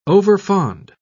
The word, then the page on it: overfond: British English